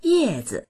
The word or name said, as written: ye4zi